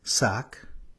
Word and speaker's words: sak: Now I'd like to draw our attention to the root of these words, sak. It's spelt S with a long A and a K.